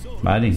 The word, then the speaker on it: Biden: Baden, só que se escreve Biden, né? Eu já ia ler Biden, deu Biden.